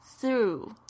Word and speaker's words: through: 注意这个 through 这个字呢 gh 是没有发音的，你常常会看到很多的这个地方哦，就是有 gh 的时候是不发音，在某一些地方呢这 gh 是发 f，但是不是这个字。